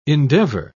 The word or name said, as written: endeavor